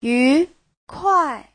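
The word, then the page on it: yukuai: yu2kuai4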